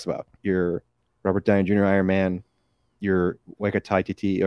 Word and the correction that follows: Taika Waititi: or Waika. Why am I not saying his name right? – We're an hour or two of the podcast, man. – Taika Waititi, that's his name.